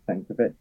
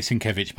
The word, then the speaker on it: Sienkiewicz: And then I didn't realise, but the Night Owl story, it's penciled by his son, and then him and Bill Sienkiewicz. Is that how you pronounce it? – Yeah, well, I say Sienkiewicz, but totally sure.